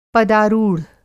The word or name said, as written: पदारूढ़